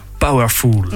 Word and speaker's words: Powherful: Est-ce que tu peux dire aux auditeurs et auditrices comment ça s'écrit Alors ça s'écrit P-O-W H-E-R-F-U-L. Donc comme l'adjectif powerful en anglais, mais avec le petit H au milieu.